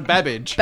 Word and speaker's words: Babbage: I do, but rhymes with cabbage. – Oh, cabbage. No, Babbage.